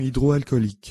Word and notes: solution hydroalcoolique: French

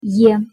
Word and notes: е: Russian

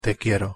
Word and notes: te quiero: Spanish

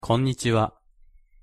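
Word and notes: konnichiwa: Japanese

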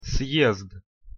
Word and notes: съезд: Russian

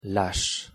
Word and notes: lâche: French

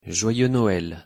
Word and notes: joyeux Noël: French

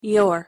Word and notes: your: English

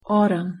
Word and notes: autumn: English

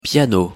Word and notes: piano: French

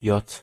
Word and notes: j: German

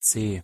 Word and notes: c: German

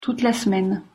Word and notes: toute la semaine: French

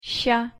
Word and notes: щ: Russian

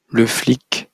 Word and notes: le flic: French

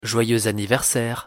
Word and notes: joyeux anniversaire: French

